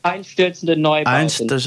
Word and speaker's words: Einstürzende Neubauten: Einstürzende Neubauten, is that how you say it? – Einstürzende Neubauten.